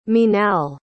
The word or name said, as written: minal